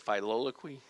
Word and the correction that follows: Filioque: Filioque and clause,